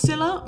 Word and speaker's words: Scylla: It's pronounced Scylla, much like Scylla Black, but spelt S-C-Y-L-L-A, and Charybdis is kind of pronounced slightly like Cardi B, but also like the name Charis, but it's C-H-A-R-Y-B-D-I-S.